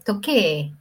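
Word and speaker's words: toque (時計): Tokei, pero de ahí no pronuncia, es toque, pero se escribe tokei.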